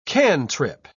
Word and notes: cantrip: British English

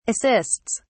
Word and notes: assists: British English

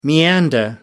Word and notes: meander: American English

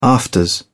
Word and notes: afters: British English

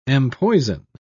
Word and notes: empoison: British English

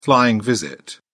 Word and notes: flying visit: British English

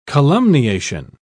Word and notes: calumniation: British English

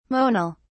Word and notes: monal: British English

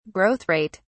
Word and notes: Growth rate: British English

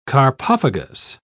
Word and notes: carpophagous: British English

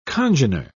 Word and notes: congener: British English

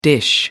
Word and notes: dish: American English

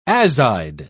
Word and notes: azide: British English

